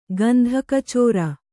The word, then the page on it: ಗಂಧಕಚೋರ: gandha kacōra